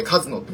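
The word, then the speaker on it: 鹿角: お前、前らどこ校だよって言ってやりましたけど、僕は。で、まあ、この登場人物、カズノセイラって、あの、鹿の角って書く、書いてカズノって読むんですけど、うん。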